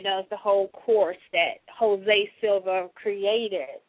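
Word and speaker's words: José: And I think it's Jose, because it's spelled J-O-S, and it has E, and it has a hyphen above the E. That's Jose, right? – Yeah, Jose Silva.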